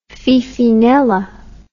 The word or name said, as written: Fifinella